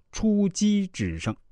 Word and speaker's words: 出奇制胜: 两军对阵，先以正和，正兵合战，双方主帅在后面看着，到了关键的时候，投入预备队，也就是机兵，最终决胜啊，这就叫出击制胜。啊！但是现在大家都念成出奇制胜，那鸡啊也就成了奇，所以我们现在也只能是将错就错了。后面如果我读错了，大家也不要怪我啊。